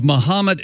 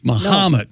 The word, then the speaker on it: Mahomet: The H-O is pronounced like ha, not like ho. – So it's not Mahomet? – It's Mahomet.